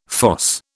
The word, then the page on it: fosse: British English